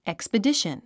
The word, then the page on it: expedition: èkspədíʃən; エクスペディション